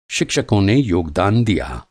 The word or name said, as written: योगदान